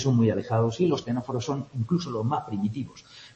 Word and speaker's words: ctenóforos: Estos ctenóforos y nidarios, los dos que empiezan por una C que no se pronuncia, ctenóforos y nidarios, son en los que aparece la neurona.